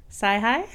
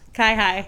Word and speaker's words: CIHI: Guess how you pronounce the acronym. – Sci-hi? – Nope. – Chi-hi? – Yes. – Chi-hi. – I would have never guessed the hard C. But it's chi-hi.